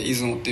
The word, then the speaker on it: 出雲: 出る雲と書いて出雲って読んじゃう